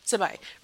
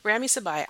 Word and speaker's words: Sabai: And I feel like they, like the guy behind Sammy, Rami Seb, how do you say his last name? – Sabai. – Rami Sabai.